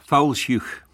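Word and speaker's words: Fowlsheugh: I've been lucky enough to go to, I don't know if I'm going to pronounce this right, Fulshire, Foulshugh. Foulshugh.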